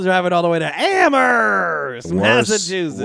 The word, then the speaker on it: Amherst: We know it's pronounced Amherst. – Yeah. – You don't put the H in there.